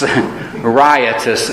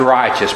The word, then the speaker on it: riotous: and there wasted his substance with riotous, not righteous, riotous living. A lot of times that's pronounced righteous, but you don't waste your substance on righteous living,